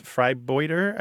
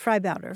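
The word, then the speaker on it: vrijbuiter: And it's pronounced freibouter, I believe. – Freibouter.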